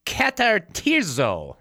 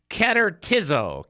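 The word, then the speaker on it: katartizo (καταρτίζω): And I want to harp on that for a second, because that word prepare is katartizo. I said that wrong. Katartizo. Katartizo. That's what it is.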